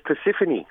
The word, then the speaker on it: Persephone: Is the name Persephone or Persephone or what is it? – No, well, very close. Persephone, I believe, is how it's pronounced.